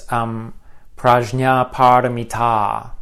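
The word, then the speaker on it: prajñāpāramitā: And I should emphasize, it's prajna paramita. The long a at the end is significant because it indicates that it's a feminine word.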